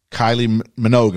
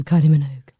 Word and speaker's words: Kylie Minogue: Kylie Minogue, am I saying it right? – Yes. – Oh, okay. – Yes, Kylie Minogue.